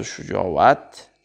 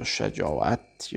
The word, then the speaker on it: شجاعت: شجاعت جمع است یا شجاعت یا شجاعت که گفتیم هر سه تلفظ صحیح هست